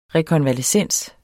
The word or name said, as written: REKONVALESCENSEN